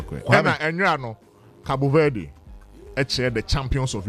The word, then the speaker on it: Cabo Verde: Cabo Verde. – That is the, and that's the right way.